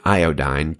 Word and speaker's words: iodine: As one of my favorite preachers, a British man named Dick Lucas says, these letters of Jesus are like iodine put on a cut. Now, he's saying iodine to us Americans, but I believe he pronounces it like iodine with an E, and I like his pronunciation better.